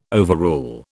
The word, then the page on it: overrule: British English